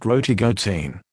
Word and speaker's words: rotigotine: Rotigotine, pronounced Rotigotine.